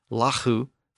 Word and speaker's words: lagu: And L A G U, pronounced Lahu, became Law, L A W.